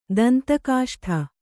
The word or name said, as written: ದಂತಕಾಷ್ಠ